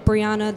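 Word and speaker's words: Briahna: It's Brianna. That's B-R-I-A-H-N-A.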